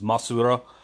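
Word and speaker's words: Mosura: And then there's uh, whenever he went up against Mothra, that's Masu Goji, which is a combination of the Japanese pronunciation of Mothra, which is Masura, and Godzilla, so Masu Goji.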